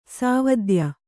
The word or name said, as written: ಸಾವದ್ಯ